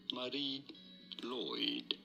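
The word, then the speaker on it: Mari Lwyd: In Welsh, this is said as Marie Lloyd. – Lloyd? – Lloyd? – Lloyd? – Lloyd? – Man, somebody told me wrong. – Mary Lloyd. – How do you spell it? – Mary Lloyd. – M-A-R-I.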